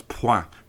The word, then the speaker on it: point: I said UK nil point, and that's point pronounced French style.